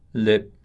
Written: lip

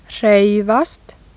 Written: hreyfast

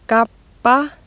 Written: gabba

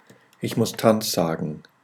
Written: Tanz

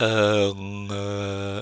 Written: enge

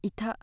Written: ita

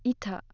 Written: ita

